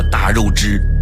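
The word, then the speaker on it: 大月氏: 汉武帝决定找的合作伙伴，是一个叫做大肉汁的国家。这个大肉汁是什么样的国家呢？大是大小的大，肉是月亮的月，汁是姓氏的氏。有兴趣的朋友可以查一下，这个在古代读作大肉汁，是一个国家名。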